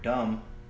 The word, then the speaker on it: dumb: But another good one is the word dumb. To me, it should be spelled like you say the word dummy. D-U-M-M. Dummy. But for some reason, in English, it's D-U-M-B. What's the B for? – You're asking the wrong guy.